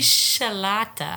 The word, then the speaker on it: michelada: Michelada.